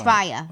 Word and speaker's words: Raya: Is it Raya or Raya? – Raya. Raya. It's Raya. – Okay. It's spelled R-A-Y-A. – It's Raya. – Okay, Raya. R-A-Y-A.